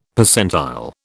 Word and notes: Percentile: British English

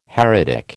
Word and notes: heretic: American English

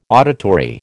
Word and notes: auditory: American English

